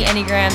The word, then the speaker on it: Enneagram: Yes, that is how you pronounce it if you've been seeing on an Instagram, and I've been wondering how the heck do you say that word. Yes, it is Enneagram.